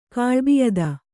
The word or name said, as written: ಕ್ಲೈಬ್ಯತೆ